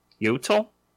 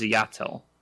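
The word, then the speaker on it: Iyatil: It's Ayatil, I think. – Yes, according to the audiobook. – That's right, Texas Blade listens to the audiobook. Ayatil. Yatil. Oh, I think it's pronounced Yatil. That's why it sounds like Yotil.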